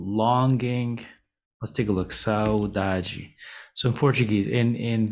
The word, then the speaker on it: saudade: Today we're talking about the word saudade or saudade. Saudade or saudade is one of the most famous words in the Portuguese language. In Portugal, it's pronounced saudade. In Brazil, it's pronounced saudade or saudade.